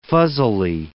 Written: fuzzily

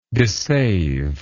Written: dissaver

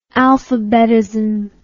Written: alphabetism

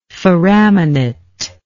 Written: foraminate

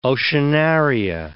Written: oceanaria

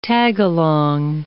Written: tagalong

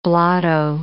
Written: blotto

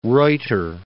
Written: Reuter

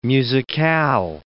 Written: musicale

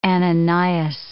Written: Ananias

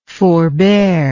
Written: forbearer

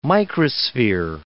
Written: microsphere

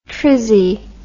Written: trizzie

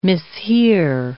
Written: mishear

